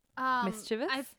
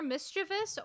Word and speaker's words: mischievous: Mischievous? – You'll hear mischievous or you'll hear mischievous. Which I think mischievous is more common, but mischievous sounds like fancier.